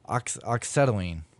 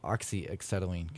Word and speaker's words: oxyacetylene: I've always heard people pronounce the song Oxacetylene, but it looks like to me it's spelled Oxyacetylene, kind of like, you know, the welding thing. But I'm going to pronounce it oxyacetylene because that's the way it looks like it's spelled to me.